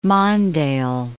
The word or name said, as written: Mondale